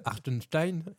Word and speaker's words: Hartenstein: Hartenstein, j'aime... si tu veux bien le prononcer, il faut penser que tu es un peu un nazi qui appelle des juifs. Quand il fait une liste dans un stade.